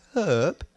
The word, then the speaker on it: herb: I would say herb. A herb, we have herbs in our cooking.